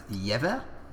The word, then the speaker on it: Jever: I believe it's pronounced Jever. – Correct. – A Pilsner. From Germany, spelled P-I-L-S-E-N-E-R.